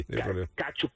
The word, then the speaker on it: Tkachuk: Se pronuncia así, tiene una T atravesada, Caferro, perdóname. – Tranquilo, sí. – Tkachuk. Se pronuncia como, es TKA, entonces es como Tkachuk, algo así.